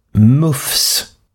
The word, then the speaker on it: MUCF: Och det här resulterat då i att Myndigheten för ungdoms- och civilsamhällesfrågor, MUFS, M-U-C-F, det skrivs ofta i tidningsartiklarna med små bokstäver, vilket antyder att man ska uttala det MUFS,